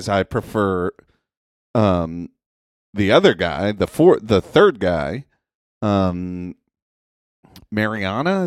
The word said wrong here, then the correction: Marini: is that how you say his name? – Marini. – Marini? Yeah, Marini.